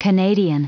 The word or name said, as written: canadian